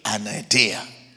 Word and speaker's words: anaideia: Yes, it's spelled A-N-A-I-D-E-I-A. Anadeia.